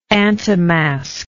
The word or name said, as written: antimasque